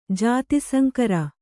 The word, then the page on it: ಜಾತಿಸಂಕರ: jāti saŋkara